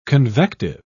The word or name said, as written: convective